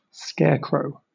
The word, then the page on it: scarecrow: British English